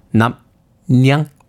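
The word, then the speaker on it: 납량: 발음을 납양이라고 할 수도 있고요. 남양. 뭐 납리앙 뭐 이렇게 할 수도 있습니다만 정확한 발음은 남냥이라고 합니다.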